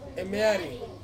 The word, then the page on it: mieri: Ansus